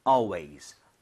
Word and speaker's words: always: Always보다는 AL이라고 하는 건 all에 가깝게 입모양 조금만 벌려주세요. 자, 이런 식으로. Always.